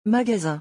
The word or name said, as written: magasin